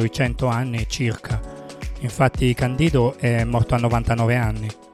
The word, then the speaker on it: Candido: Voglio fare un altro omaggio a un altro dei miei artisti preferiti dell'epoca, Candido Camero Guerra, conosciuto semplicemente come Candido. L'accento va sulla I perché non è una parola anglofona, bensì lui è di origine cubana, quindi questo spiega tutto.